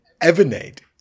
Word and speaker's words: Avanade: So that's Huawei and Avande, which is a joint between Microsoft and Accenture. – No. You pronounced both of them wrong. So it's Huawei and Avanade.